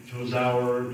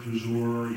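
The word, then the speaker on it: Tozour: But Tozer, most people say Tozauer, Tozer, Tozur, you know, whatever.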